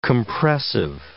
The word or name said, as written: compressive